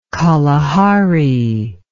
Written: kalahari

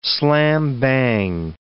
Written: slam-bang

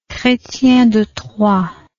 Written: chrestien de troyes

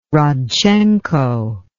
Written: rodchenko

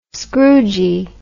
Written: scroogie

